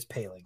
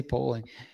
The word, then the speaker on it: Poling: Jack Palin. – It's Ryan spelled Poling, but apparently it's Palin. – I keep wanting to say Poling.